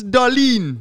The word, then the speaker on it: Dahlin: Am I saying that right? Dahlin. – Rasmus Dahlin.